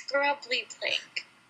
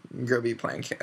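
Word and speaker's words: Grubbly-Plank: "It's grubbly plank." – "No, you're right. Grubbly plank." – "No, you keep saying grubby. She's not grubby." – "It's what it looks like. Grubby plank. Okay." – "No, say it right."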